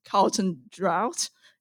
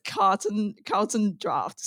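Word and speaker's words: Carlton Draught: It's come to my attention that the popular Carlton United Brewery brand, despite looking like it's pronounced Carlton Drought in every way, it was actually pronounced Carlton Draft.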